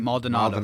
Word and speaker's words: Maldonado: Maldonado.